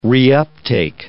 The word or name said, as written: reuptake